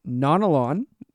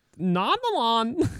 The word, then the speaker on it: Nanalan: So today we are covering Nanalan. – Nanalan. – There we go. You just have to say it. Like really high octave. – Nanalan.